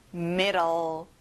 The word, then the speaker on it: middle: 중간, 가운데, middle. 자, D 발음을 보통 L, L 발음으로 해보세요. Middle.